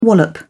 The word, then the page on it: wallop: British English